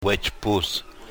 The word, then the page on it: Wechpus: yurok